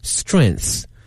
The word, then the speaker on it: strength: 它的发音是 strength。后面这个 th 一定把这个舌头哈咬在上下齿之间哈 ,strength, 它由这个词 strong, 强壮的形容词呢变成了名词，表示一个人的力量啊。